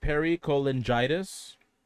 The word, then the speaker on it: pericholangitis: It's pronounced pericholangitis, which is an inflammatory condition of tissues surrounding the bile ducts of the liver.